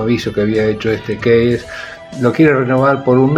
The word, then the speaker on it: Keyes: Así que Bloom, como es vendedor de, avisos publicitarios, tiene que cerrar trato con un cliente que se llama Alejandro Keyes, que se escribe K-E-Y-E-S, casi como si fuera keys en inglés, que quiere decir llaves.